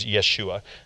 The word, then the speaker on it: Yeshua: You might have heard people calling the name of Jesus Yeshua. That is a Jewish pronunciation of the same name.